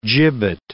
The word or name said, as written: gibbet